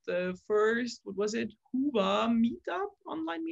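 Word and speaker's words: Hyvä: Hiva. Is it Hoofa pronounced? – Obviously, you haven't seen episode one of Talk Commerce, where we spend at least 15 minutes pronouncing it. – Really? – It's Hoofa.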